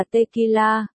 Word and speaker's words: tequila: Hứa Phương Chu, một, Long Thiệt Lan, tên tiếng Anh là Tequila. Phát âm như tequila, là một thứ rượu trưng cất có độ cồn cao truyền thống của Mexico.